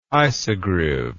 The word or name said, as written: isogriv